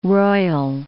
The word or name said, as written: royal